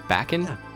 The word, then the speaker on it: Bakken: So the first amusement park is believed to be in Bakken in... Did I say that wrong? Bakken? – Yeah, Bakken in Denmark.